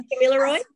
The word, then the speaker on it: Gamilaroi: Her people are the Gamilaroi country. Did I say that right? In Gamilaroi, really close.